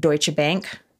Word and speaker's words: Deutsche Bank: who is a trader at Deutsche Bank. Am I saying that right? – Yep, you are.